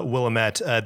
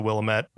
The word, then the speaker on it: Willamette: Fun fact about Willamette, they pronounce it Willamette in the game. Everyone calls it the Willamette Mall, but there actually is a town in Washington called Willamette, spelled the same way, but they pronounce it Willamette.